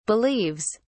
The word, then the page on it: believes: British English